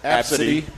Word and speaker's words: ABCDE: And just so I know you're curious, but they pronounce it Absidy.